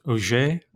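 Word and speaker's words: Ogé: You speak at length about an insurrection led by Vincent Auger. Am I pronouncing that right? – That's right.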